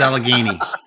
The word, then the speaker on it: Allegheny: But yes, and I can actually say it right, Dan. – I can. Trans Allegheny. I did it. Trans Allegheny, right? – You got it.